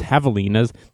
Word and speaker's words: javelinas: So I peek my head out, and it's these javelinas, or spelled javelinas, but I guess they're pronounced javelinas, these pig-style beings that exist in the South.